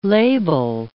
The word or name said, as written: label